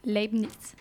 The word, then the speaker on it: Leibniz: Comment Leibniz. Leibniz.